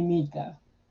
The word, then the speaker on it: Neeka: spell it N-E-E-K-A. And I did it that way so that when people say it, they're pronouncing my name right, Wealthy Nika.